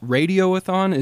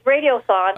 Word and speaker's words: radiothon: So um, I understand the guest house also does an impressive radioathon. Am I saying that correctly? Is it radioathon or radiothon? It's radiothon.